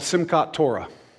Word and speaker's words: Simchat Torah: The Jewish people, they celebrate a holiday called Simchat Torah. I was told I did pronounce that correctly when I said in the first service.